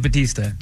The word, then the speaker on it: Bautista: Dave Bautista.